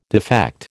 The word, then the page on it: defect: American English